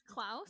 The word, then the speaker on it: Klaus: It's Klaus.